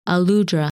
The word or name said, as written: Aludra